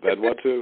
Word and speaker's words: Vanuatu: I think that's how you pronounce it, Vanuatu.